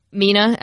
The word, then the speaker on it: Mina: I'm Mina, M-I-N-A, and my whole life my name's been mispronounced and misspelled, and I just took it because I'm a beta.